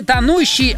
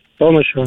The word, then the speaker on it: тонущего: Поэтому правильно говорить не тонущий, а как еще раз? – Тонущего.